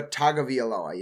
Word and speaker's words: Tagovailoa: Tua, you're missing a syllable in his name. What? – Tua Tagovailoa. You're missing a syllable. You're putting one too few syllables in his last name.